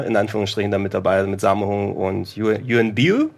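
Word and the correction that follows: Yuen Biao: Yuan Biao. Biao. – Biao spricht man es aus.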